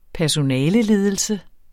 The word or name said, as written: personaleledelse